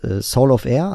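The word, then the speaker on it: Solovair: Genau, dann gibt es noch einen Laden, beziehungsweise gibt es noch einen Hersteller, der heißt Soul of Air, also Solovier. Solovier wird der geschrieben, aber ausgesprochen Soul of Air.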